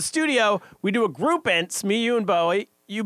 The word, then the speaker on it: Ense: E-N-S-E is how you spell it. – A new chant. E-N-S-E. Ents. – E-N-S-E. Ents.